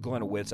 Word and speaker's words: Gliniewicz: It's actually spelled Gliniewicz. It's Gliniewicz, I think, is the correct pronunciation.